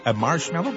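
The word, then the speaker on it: Marshmello: La preuve que vous êtes au meilleur de la musique, c'est Marshmallow, comme ça on le prononce. – Ouais, tu l'as bien, ouais.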